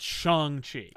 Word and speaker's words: Shang-Chi: Outside of that, Adam says, I hear a lot of people pronouncing it Shang-Chi, or it sounds like that. Anyone know what the correct with what's correct with the movie coming out? The actor who's playing him says it's Shang-Chi.